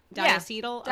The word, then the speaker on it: diacetyl: So nicotine, which we're going to talk about, ultra-fine particles that could be inhaled deeply into the lungs, flavorings such as diacetyl. Am I saying that right? I've only ever read it. – Yeah, diacetyl? – Yeah. Diacetyl, yep.